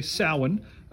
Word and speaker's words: Samhain: Now, I don't speak that language, so I pronounced it Samhain for a long time and was corrected, so it's actually Samhain, and it goes back same date as Halloween, give or take a day.